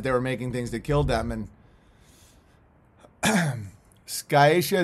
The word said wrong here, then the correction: sciatica: is pronounced sciatica.